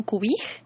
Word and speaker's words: Kupijy: Me llamo Cubij y significa flor que asciende en Mije. Entonces, eh, se escribe Cupiji, pero se pronuncia Cubij por algunos vocablos en Mije, ahí al ser traducido.